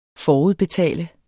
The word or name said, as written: FORUDBETAL